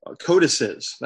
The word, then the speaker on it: codices: Codices, that's how you say it in plural, that are our oldest and most complete manuscripts of the Bible, Dr. Minnick says.